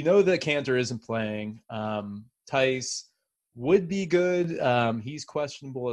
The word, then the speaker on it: Theis: Your boy Daniel Theis, is it Theis? Is that how he pronounces it? – No, Daniel Theis. – Theis, I knew that.